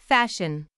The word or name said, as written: fashion